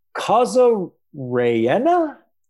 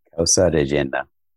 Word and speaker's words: Causa rellena: Causa rellena? – Causa rellena. – Oh boy, I butchered that one bad.